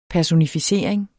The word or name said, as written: PERSONIFICERINGERNES